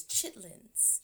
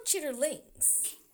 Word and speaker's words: chitterlings: I actually don't think it's chitlins. Chitlins. – Oh right, yeah, but but it's spelled chitterlings.